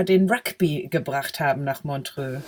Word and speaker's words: Montreux: Habe ich das richtig ausgesprochen? – Montreux. Ja.